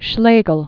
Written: August Wilhelm von Schlegel

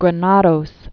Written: Enrique Granados

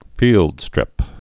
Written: fieldstrip

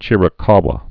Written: Chiricahua

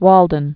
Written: Walden Pond

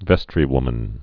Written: vestrywomen

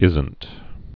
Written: isn't

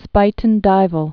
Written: Spuyten Duyvil Creek

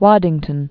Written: Waddington, Mount

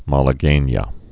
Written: malaguenas

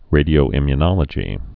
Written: radioimmunological

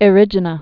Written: Erigena

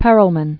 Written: S. J. Perelman

